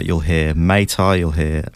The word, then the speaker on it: Muay Thai: Muay Thai. – Muay Thai.